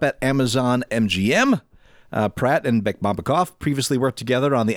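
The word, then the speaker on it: Bekmambetov: You're just stringing syllables together now. Bekbombekov. – That is spot on. It's Timur that I didn't hit quite right.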